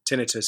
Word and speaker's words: tinnitus: So, yeah, the highest percentage of serious side effects from the COVID vax is, I don't know how to say it, tinnitus? – Tinnitus.